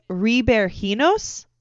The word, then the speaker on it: Ribeirinhos: So this is going to be a Brazil and Portuguese pronunciation adapted into an American accent. So here we go. It is Ribeirinhos.